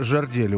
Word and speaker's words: жердели: Жердилы, жердели. Ударение на первый слог.